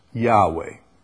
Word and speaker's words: Yahweh: The Hebrew letters YHWH, Yod, He, Wah, He, YHWH, is probably best pronounced Yahweh.